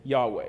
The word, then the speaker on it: Yahweh: It's the best we can think to pronounce it is Yahweh.